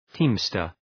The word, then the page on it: teamster: ‘ti:mstər